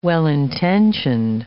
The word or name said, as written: well-intentioned